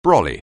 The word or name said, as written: brolly